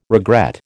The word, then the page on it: regret: American English